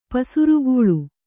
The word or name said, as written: ಪಸಿರ್ಗಲ್